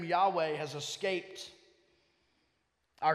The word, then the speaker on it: Yahweh: Over time and by choices by Bible translators, and not necessarily Bible translators, just by scholars, we've landed on Yahweh being the pronunciation of the word that's used here.